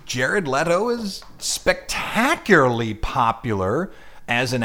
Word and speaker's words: Leto: Jared Leto. I said right. Leto. – I have a student named Leto, and it's spelled like Leto. That's the reason I did that.